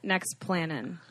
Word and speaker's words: Nexplanon: So this woman is on Nexaplon. Is that how you pronounce it, Shannon? – Yeah, it's what goes in. It's the one that goes under your arm. – I've been on what is that? Nexplanon. What is it? – It's just a hormonal implant.